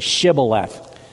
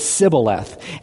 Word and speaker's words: Shibboleth: Good thing, because all of the Ephraimites who were from a different place couldn't pronounce it properly. They had an accent, and they said Sibboleth. And when they said it wrong, then they executed them. That's the way they could tell all the spies and the guys from the other side, because they were from a different region, from the other side of the Jordan, and they didn't say it right.